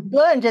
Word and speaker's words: Slunj: And I want to mention the little village, Slunj. Am I pronouncing that right? – Slunj.